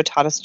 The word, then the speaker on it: Tatis: So, speaking of prospects getting the call-up, though, Fernando Tatis Jr. is actually going to make the opening day roster for the Padres. – It's pronounced Tatis. – Is it really? – Yep, it is. Thank you.